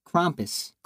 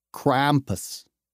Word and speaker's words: Krampus: That's because Norman has been tearing up this Krampus. – I have Krampus or Krumpus? – Krampus. – I can't do it. – Say it with a hard A. – Krampus.